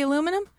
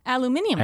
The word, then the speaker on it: aluminium: Do you hear the way the Brits say aluminum? They say aluminium.